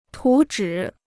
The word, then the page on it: 图纸: tú zhǐ